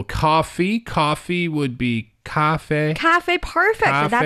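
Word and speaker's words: 咖啡: It's the tones. – Yeah. – It's a little higher, right? – Yes. – Cafe, cafe. – Mm-hmm. – And it's the same tone for the first syllable and the second syllable.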